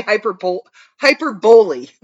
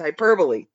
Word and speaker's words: hyperbole: I always want to say hyperbole, when I see that word, but it's hyperbole. I'm putting the emphasis on the wrong syllable.